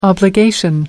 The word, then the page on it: obligation: American English